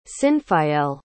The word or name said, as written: cynfael